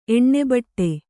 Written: ಎಣ್ಣೆಬಟ್ಟೆ

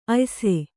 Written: ಆಯಾಸ

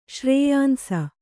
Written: ಶ್ರೇಯಾಂಸ

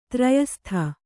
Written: ಧೈರ್ಯಸ್ಥ

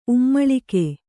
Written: ಉಮ್ಮುಳಿಗೆ

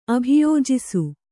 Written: ಅಭಿಯೋಜಿಸು

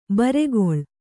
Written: ಬುರುಗಲು

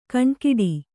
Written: ಘನಘಟ್ಟಿ

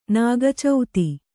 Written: ನಾಗಚೌತಿ

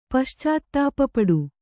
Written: ಪಶ್ಚಾತ್ತಾಪಪಡು

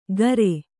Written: ಕಾರೆ

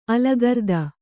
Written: ಅಲಗರ್ದ

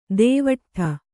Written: ದೇವಟ್ಠ

